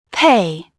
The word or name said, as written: pay